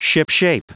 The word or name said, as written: shipshape